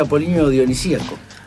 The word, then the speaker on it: apolíneo-dionisíaco: ¿Qué es un momento apolíneo con sindicíaco, sindicalista? – ¿Cómo se dice? ¿Podés repetir lo que dijiste? – Claro, es un contraste apolíneo-dionisíaco.